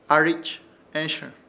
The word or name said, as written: ARICH ESHƗR